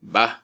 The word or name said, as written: ba